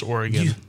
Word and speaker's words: Oregon: It's Oregon. Pronounced Oregon.